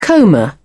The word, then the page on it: coma: British English